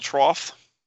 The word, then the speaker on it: trough: The problem is, what he has, most of his problem is, the ball trough. I said that? Yes. – Yeah, you said it right.